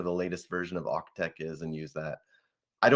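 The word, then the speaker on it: AUCTeX: So were you saying OCTech, like AUC tech? – Oh, did I not pronounce the C? OCTech. – I thought you said org tech. I wasn't familiar with that. OCTech is the only one I've ever used.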